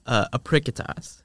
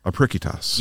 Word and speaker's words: Apricitas: So the newsletter is Apricitas. – Apricitas. – Yes. It's a K sound, but it's spelled with a C because it's a Latin word.